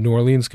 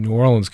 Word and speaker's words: New Orleans: But, you know, every now and then I write a page and all the New Orleans guys in the, or I should say New Orleans guys, I'm pronouncing correctly,